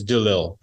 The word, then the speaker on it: Delisle: It's a French name. If you talk to my English side of my family, half my family's English, half my family's French. If you talk to the English side, that's how they say it. French side says Delisle, but you got Delisle. – Okay, but the S is out no matter what. – The S is silent.